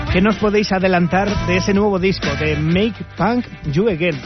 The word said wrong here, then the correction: Make Punk Young Again: No sé si lo he pronunciado bien, ¿eh? – Sí, pues bueno, como llevamos diciendo un tiempo, durante toda la entrevista, es, pues al final es Make Punk Young Again, para que los chavales vuelvan a ser punkies, vuelvan a eso y dejen, bueno, sin más, dejen lo que quieran, vamos.